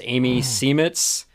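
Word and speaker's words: Amy Seimetz: So I think her name is pronounced Amy Semetz.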